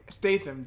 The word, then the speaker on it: Statham: Statham.